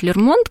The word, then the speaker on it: Лермонт: Ну, сейчас принято говорить Лермонт, как-то устоялось ударение на последний слог.